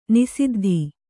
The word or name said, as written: ನಿಸಿದ್ದಿ